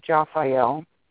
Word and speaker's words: Jophiel: And I wanted to introduce one of the archangels for you, and her name is Japhiel, and that is spelled J-O-P-H-I-E-L.